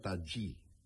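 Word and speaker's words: Tajy: Se escribe Tají con J, Y, pero me han dicho que se pronuncia Tají.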